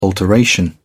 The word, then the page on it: alteration: British English